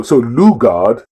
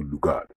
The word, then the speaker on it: Lugard: So Lugard is the proper pronunciation as opposed to Lugard, which we Nigerians tend to.